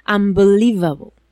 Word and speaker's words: Unbelievable: Espérate, lo he dicho mal. La V la pronuncio mal. ¡Unbelievable!